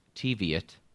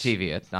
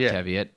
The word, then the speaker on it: Teviot: I found out today it's pronounced Teviot. – Yes. – Teviot, not Teviot.